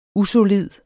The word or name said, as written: usolid